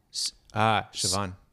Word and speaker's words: Siobhan: Siobhan. – Siobhan? Mm-hmm. Is that how we pronounce it? – Yep.